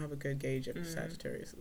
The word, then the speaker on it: Sagittarius: I didn't know how to say this one for the longest time. – Sagittarius. – I mean, I just said that, nigga, but like, I heard people call them Sagittarius terrorists.